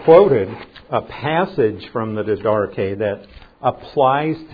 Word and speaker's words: Didache: It's spelled D-I-D-A-C-H-E. Didache. Don't ask me why they pronounce it that way. But that's what it's pronounced as, Didache.